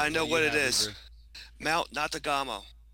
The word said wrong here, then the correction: Natagumo: Oh, that's not right. You got to pronounce it right, dude, if you're going to say it, because it's N-A-T-A-G-U-M-O? Natagamo? Yep. – Natagumo.